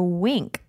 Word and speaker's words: Winc: We would like to thank our sponsor, Wink. Wink makes it easy to discover great wine. It's spelled W-I-N-C. Not like a wink you give someone when you're flirting. – Right. – Wink's its own thing.